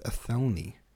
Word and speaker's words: Athelney: I kept saying Athenely, I think, but it's Athelney.